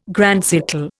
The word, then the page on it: grand siècle: Indian